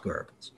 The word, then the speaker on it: Goebbels: People would say Goebbels. It's actually pronounced Goebbels.